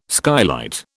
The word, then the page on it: skylight: British English